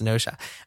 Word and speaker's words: Gnosia: I am also playing a game that I've talked about before, and it is a game called Gnosha, with a G. I think it's just pronounced Gnosha.